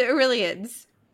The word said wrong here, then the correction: Illyrians: I'm saying that right, right? – Illyrians. – Illyrians, my God.